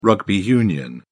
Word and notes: rugby union: British English

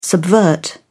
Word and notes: subvert: British English

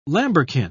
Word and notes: lambrequin: British English

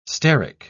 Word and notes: steric: British English

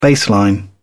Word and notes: baseline: British English

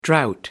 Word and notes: drought: American English

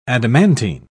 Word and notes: adamantine: British English

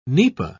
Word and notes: nipa: British English

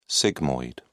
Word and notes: sigmoid: British English

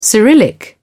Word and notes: cyrillic: English; British English

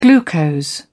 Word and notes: glucose: British English

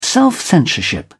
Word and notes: self censorship: British English

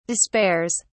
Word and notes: despairs: British English

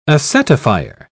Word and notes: acetifier: British English

